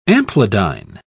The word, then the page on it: amplidyne: British English